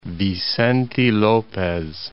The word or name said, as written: Vicente Lopez